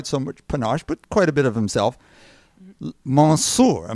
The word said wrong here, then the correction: Monsieur: Am I doing that pretty close to the French pronunciation, Stephanie, or am I way off? – Monsieur. – Monsieur.